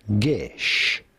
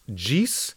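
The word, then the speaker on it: geas: It is not pronounced Gees or Gees or Gees. Or gayass is pronounced gish.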